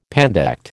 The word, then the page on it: pandect: American English